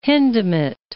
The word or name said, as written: Hindemith